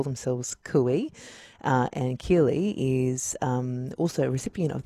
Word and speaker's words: Kirli: They're led by a proud Gunai woman called, I think it's pronounced Kirli. It's K-I-R-L-I.